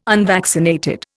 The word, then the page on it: unvaccinated: Indian